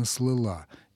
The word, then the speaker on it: слыла: Ярмарка слыла или слыла? Конечно, слыла.